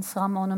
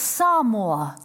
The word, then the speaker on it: Samoa: People, it's Samoa, not Samoa. You learned a thing. It's Tonga, not Tonga. The G is silent in Polynesian languages.